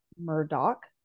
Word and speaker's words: Murdaugh: Yeah, so it looks like Murdaugh, but I believe it's a Scottish name, and the actual pronunciation is Murdaugh.